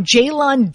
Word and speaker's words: Jalon: Jalon Daniels. He's an undrafted rookie, I believe, from Kentucky. – He's from Kansas. – From Kansas, sorry. – Yeah, I evaluated him in detail coming out. I love Jalon Daniels.